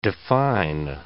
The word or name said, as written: define